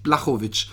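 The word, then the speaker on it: Błachowicz: Ich habe schon das Gefühl gehabt, dass Blachowicz, wie sie ihn in Amerika nennen, dabei heißt der hundertprozentig Blachowicz. – Er heißt Blachowicz und eigentlich nennen ihn auch alle so. – Nee, sagen alle Blachowicz, weil sie natürlich nicht aussprechen können in Amerika.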